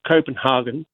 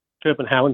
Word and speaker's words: Copenhagen: So, well, might I just mention too, the place is not called Copenhagen. It's called Copenhagen. Actually, it's called Copenhagen. Copenhagen is how they pronounce it.